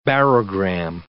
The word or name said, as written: barogram